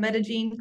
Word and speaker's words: Medellin: I am from Medellin, Colombia. That's how you pronounce it.